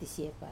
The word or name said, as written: tasiepar